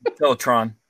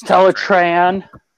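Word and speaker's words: Teletraan: Like people confuse with Teletron and Teletran. – Teletron. – It's Teletran.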